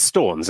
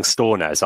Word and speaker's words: Stornes: Right, is it Storns or Stornes? – Casper It's Storns, isn't it? – Yeah. – Right, the commentators kept saying Stornes. I was like, where have you got this extra syllable from?